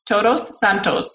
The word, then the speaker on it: Todos Santos: Todos Santos.